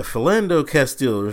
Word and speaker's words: Philando Castile: Off of that to another police matter, Philando Castile. I think it's Castile. Hold on, let me get his name right. Yeah, Philando Castile.